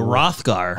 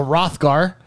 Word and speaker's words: Hrothgar: I'm a Rogarer. I don't know how to pronounce the race that I am. – What? Oh, a Hrothgar. – A Hrothgar. I'm a Hrothgar.